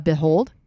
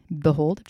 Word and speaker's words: Beihold: Behold. – How do you spell it? – B-E-I-H-O-L-D. Behold.